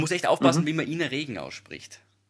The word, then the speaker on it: Ina Regen: Du musst, du musst kein stummes Haar dazu dichten. – Du kannst doch einfach so, genauso wie es ist, einfach halt nur ein bisschen im Dialekt aussprechen. – So. – In Are. – Okay. Ja. – Hast kein Buchstaben dazu.